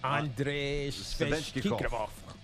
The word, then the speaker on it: Svechnikov: Andrei Svechnikov.